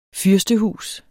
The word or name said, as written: FYRSTEHUSENES